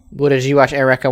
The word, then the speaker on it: Eureka: It's Eureka. That's how that word is pronounced in America.